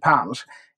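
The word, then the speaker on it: punsch: så blev det en punch. Man bara släppte, precis som lunchen i dagligt tal uttalas lunch, så blev punchen punch.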